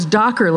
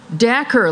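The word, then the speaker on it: Dacher: Couple of years, so I thought it was Docker, like Dockers that you wear, but turns out it's Dacker, like Snacker.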